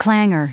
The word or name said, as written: clanger